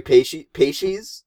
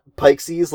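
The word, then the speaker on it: Peixes: The pronunciation is Feferi Pacies, Okay. – not Pikesies, like I said for the better part of 9 years.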